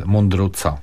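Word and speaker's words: Mundruczó: A to nawet nie wiedziałem, ale w każdym razie wymawia się go Kornel Mundruczo.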